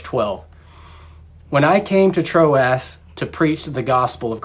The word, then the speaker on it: Troas: When I came to Troas to preach the gospel of Christ,